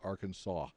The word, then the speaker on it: Arkansas: Since the letter S is at the end of the French words are usually silent, we pronounce Bill Clinton's home state Arkansas.